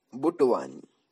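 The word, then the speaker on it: Butwani: बुटवान बुटवानी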